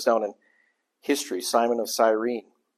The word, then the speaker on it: Cyrene: So this guy, Simon of Cyrene, as my wife correctly pronounced, was recruited.